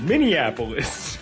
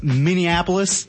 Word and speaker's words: Minneapolis: And Minnesota is where the Minneapolis is. – It's pronounced Minneapolis. – Yeah, that's what I said.